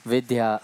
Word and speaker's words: Vidya: She writes V-I-D-Y-A. – Ona to píše V-I-D-Y-A. – But we pronounce it V-I-D-H-Y-A. – Ale my to... – Vidya, dha. – Pro ve skutečnosti vyslovujeme s tím dha, vidya.